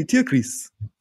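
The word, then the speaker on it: Tierkreis: It's actually Tyclis.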